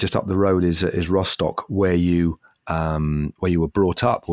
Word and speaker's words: Rostock: Now it's time for the Rostock quiz, which is locally pronounced Rostock.